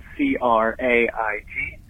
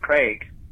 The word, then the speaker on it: Craig: Uh, just wanted to chime in and say that my name, which is spelled C R A I G, is supposed to be pronounced Craig, but a lot of people say Craig as if it rhymes with Greg. It doesn't.